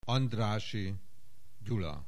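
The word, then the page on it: Andrássy Gyula: Hungarian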